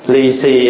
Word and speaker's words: lì xì: Lì xì is come from a Chinese word, lì xì.